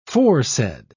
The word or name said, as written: foresaid